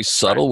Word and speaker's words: Subtle: Well, I think you've gone a long way to getting people to fall in love with the book called Subtle Words, Subtle. It's interesting that B is silent, if I say it correctly. Subtle right.